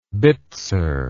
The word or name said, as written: bitser